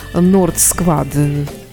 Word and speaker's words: North Squad: Продолжаем подводить, э, ну, не только подводить итоги, а вообще говорить с мотоклубом Nord Squad. RC, да, меня поправили немножко, как правильно произносить.